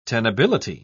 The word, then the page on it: tenability: British English